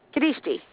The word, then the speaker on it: Kadishti: There's something you call, am I pronouncing it right, Kadishti movement? – Kadishti. Yes.